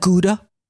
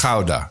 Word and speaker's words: Gouda: Is that how you're supposed to pronounce it? – Gouda? Gouda. – Not Gouda? – No. Gouda.